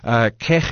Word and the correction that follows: Chegg: So I love your pronunciation. That is a South Africanism if there ever was one. we do pronounce the J as a T, but it's Chegg.